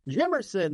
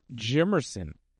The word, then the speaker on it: Jimmerson: You said Jimmonson. – Jimmerson, man. – I think I rest in peace, pioneer of the UFC, Art Jimmerson. That's what AJ is talking about. Art Jimmerson passing away.